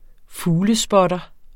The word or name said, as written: fuglespotter